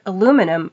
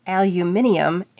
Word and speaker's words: aluminium: And Pam Spencer-Holly remembers being surprised by a number of Britishisms over the years in audiobooks, and the word aluminum pronounced aluminium in particular.